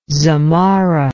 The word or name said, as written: zamarra